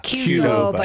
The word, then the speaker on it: Qdoba: Qdoba.